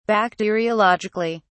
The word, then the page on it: bacteriologically: British English